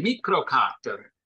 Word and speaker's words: Mikrokator: which is Swedish, so you have to pronounce it as a mikrokator.